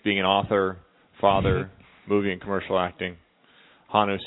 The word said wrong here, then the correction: Honu: sandals. Am I saying that right? – Honu. – Honu.